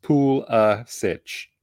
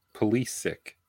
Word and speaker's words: Pulisic: Is technically supposed to be pronounced Pulisic. – All right. – However, he was born in the United States, prefers for his surname to be said as Pulisic.